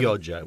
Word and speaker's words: Chioggia: Is that Chioggia. – Chioggia. – Yeah, C-H-I, right? – Yeah, yeah. – Yeah, Chioggia, yeah.